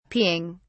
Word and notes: peeing: British English

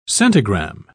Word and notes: centigramme: British English

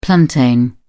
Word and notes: plantain: British English

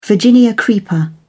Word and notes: virginia creeper: British English

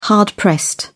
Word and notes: hard-pressed: British English

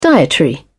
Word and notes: dietary: British English

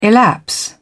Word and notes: elapse: British English

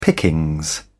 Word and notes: pickings: British English

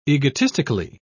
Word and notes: egotistically: British English

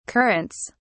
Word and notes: currants: British English

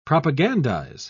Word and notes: propagandise: British English

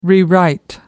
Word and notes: rewrite: American English

